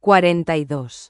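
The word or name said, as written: cuarenta y dos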